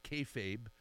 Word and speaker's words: kayfabe: Explain this word that I think is pronounced kayfabe and what that means. – Yep, that's the correct pronunciation.